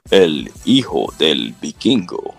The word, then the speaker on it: El Hijo del Vikingo: Chris Sabin versus Kushida versus El Hilo del Vaquino. No, I said that wrong. Pronounce it. – Yeah. – El Hijo del Vikingo.